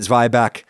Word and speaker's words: Zwieback: I pronounced it Zweibach because that is an established United Statesian pronunciation of that word. That's just how lots of people here say it. Don't believe me? Check the entry in the Oxford English Dictionary.